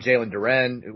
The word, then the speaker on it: Jalen Duren: And if you're saying, you know, we're going all in on Jalen Duren, which is how recruiting people tell me his name is pronounced, the five-star other big man who's coming out of a – like, that's the stupidest thing I've ever heard of.